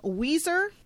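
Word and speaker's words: Ouiser: Also, the name Weezer isn't spelled W-E-E-Z-E-R like the band, or like somebody who's got emphysema. It is spelled O-U-I, like we as in yes, S-E-R.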